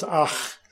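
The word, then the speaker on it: ach (אך): It's spelled A-K. It's pronounced ach. Sort of like my German grandmother would say, ach, you.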